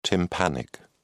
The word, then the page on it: tympanic: British English